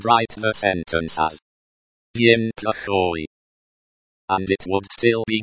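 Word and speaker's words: Plakhoy (плохой): Plakhoy can be tricky, because the O is pronounced like the O in hot, and the Y is pronounced like the Y in yes, and not like the Y in fly.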